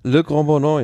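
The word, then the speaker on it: Le Grand-Bornand: in Annecy nach La Grande Bornat. Oder, oder irgendwie so ähnlich ausgesprochen. Le Grand Bornat, ja.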